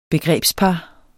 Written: begrebspar

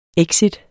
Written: EXITTEN

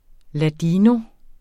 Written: ladino

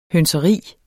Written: hønseri

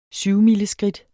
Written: syvmileskridt

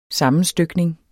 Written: sammenstykning

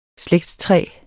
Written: slægtstræ

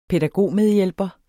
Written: pædagogmedhjælper

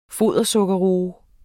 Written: fodersukkerroe

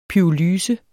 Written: pyrolyse

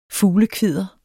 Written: fuglekvidder

